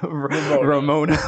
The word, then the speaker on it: Ramona: And now towards the end, we're now starting to see Ravonna have to now almost, am I saying her name right? I'm saying Ravonna. Ramona. I'm saying V. Yeah, Ramona.